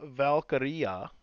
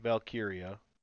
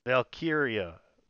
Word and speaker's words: Valkyria: Io Sky gets the pin over Shayna Baszler and Lyra Valkyria. Not Valkyria. Because that rhymes with diarrhea.